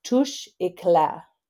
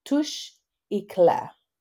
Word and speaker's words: Touche Éclat: The correct pronunciation is Touche Éclat. Touche Éclat.